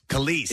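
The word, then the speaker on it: Kelis: According to The Sun magazine, the latest celebrity couple is none other than milkshake singer Kellis. Is that how you say her name? – Yes. – Kellis.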